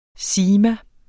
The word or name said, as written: sigma